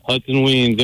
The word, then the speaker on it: Hudson Wiens: Yeah, Hudson Wiens.